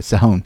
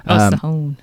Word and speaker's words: Sihon: Sihon. – Sihon. – Yeah, well, Hebrew versus English pronunciations are always weird, but I try and do justice to the Hebrew pronunciations.